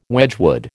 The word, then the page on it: Wedgwood: American English